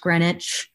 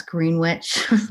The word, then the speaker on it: Greenwich: We came from middle of nowhere, upstate New York, in this small town called Greenwich, New York, not pronounced Greenwich. It's Greenwich.